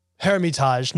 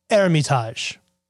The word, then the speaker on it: Hermitage: So when they brought and sent barrels for trade, they put an H in front of the E to make it easier for them to say. Hermitage, not Hermitage. I don't know.